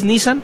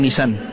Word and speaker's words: Nissan: ¿Es Nissan o Nissan? – Bueno, la pronunciación correcta, como le quieras decir, realmente, se supone que la pronunciación correcta es Nissan.